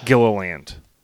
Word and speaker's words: Gilliland: Gilliland.